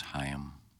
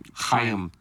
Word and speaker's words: Chaim: I think it's pronounced Chaim. – Chaim Bloom. – Chaim. Thank you. – Just for Bloom Party. – But you can say Chaim. That's acceptable.